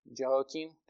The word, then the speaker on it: Jehoiakim: Jehoiakim. Am I saying that right? Jehoiakim? Thanks, Brian.